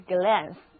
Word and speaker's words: glance: 注意元音字母 a 在这里读的是长的开口 r，而美音的话呢就是美国英语的发音，就是 glance，就是我刚才念的。